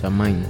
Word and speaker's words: Samhain: ¿Samhain se pronuncia? – Samhain, sí.